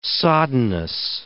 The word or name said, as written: soddenness